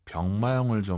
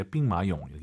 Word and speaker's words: 兵马俑: 나, 시안 가서 병마용을 좀 보고 싶네. – 我想去西安看看兵马俑。 – 兵马俑. 이런 고유명사도 알아두시면 좋아요. 병마용, 병마용 하지 마시고 이제 빙마용 예쁘게 발음하시기 바랍니다.